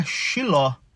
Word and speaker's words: Siló: Bom, galera, e no capítulo 18, o foco é essa reunião que está acontecendo em Siló, né? Em hebraico, a pronúncia seria Shiló, né? Então, assim, eu não sei se falo Siló ou Xiló, vamos Siló, né? Que tá aí no português e tal.